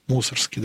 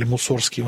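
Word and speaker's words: Мусоргский: Да, первый, кто мне приходит в памяти, да, выпускник славной гвардейской школы Модест Петрович Мусорский, да, или Мусорский в эмиграции, говорят, ударение не так ставят всегда, меня поправляли.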